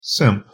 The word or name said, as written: simp